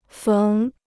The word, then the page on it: 缝: féng